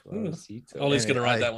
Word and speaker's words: Suavecito: It's called Suavecito. And she bought it because it rhymes with Despacito.